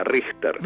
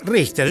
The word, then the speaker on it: Richter: La CH se pronuncia como una J, Richter. – Richter, yo lo pronuncio bien.